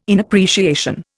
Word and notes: inappreciation: Indian

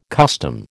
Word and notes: custom: American English

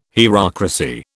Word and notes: hierocracy: British English

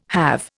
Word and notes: have: Indian

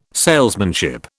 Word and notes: salesmanship: British English

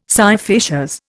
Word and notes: scire facias: Indian

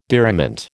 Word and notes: diriment: American English

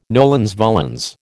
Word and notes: nolens volens: American English

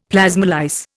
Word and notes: plasmolyse: Indian

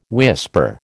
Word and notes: whisper: American English

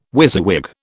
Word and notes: WYSIWYG: British English

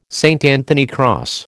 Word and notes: St Anthony cross: American English

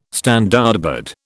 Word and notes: Standardbred: British English

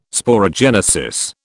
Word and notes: sporogenesis: British English

